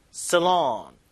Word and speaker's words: salon: 자, 요 살롱이 강세가 표시는 안 돼 있는데 뒤에 줘야 돼요. 뒤에. Salon.